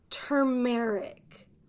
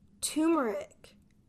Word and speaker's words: turmeric: So the first way you can say it is turmeric. Turmeric. And I've also heard people say turmeric. Turmeric. So we have turmeric and turmeric.